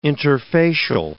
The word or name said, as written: interfacial